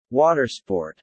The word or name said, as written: watersport